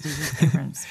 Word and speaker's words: J.J. Abrams: J.J. Abrams, lo dije bien. – Sí, J.J. Abrams.